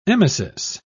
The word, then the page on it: emesis: British English